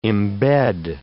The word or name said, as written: embed